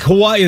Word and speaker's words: Hawaii: So apparently, the correction is that when the W is in the word itself, it becomes pronounced with a V. Right? Like, Hawaii is Hawaii is the correct pronunciation.